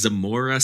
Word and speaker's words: Zamora: So your hometown is Zamora, Spain.